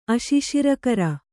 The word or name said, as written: ಅಶಿಶಿರಕರ